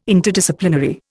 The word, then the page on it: Interdisciplinary: Indian